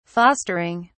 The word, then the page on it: fostering: British English